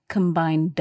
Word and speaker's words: combined: And this ED verb is pronounced D, combined D.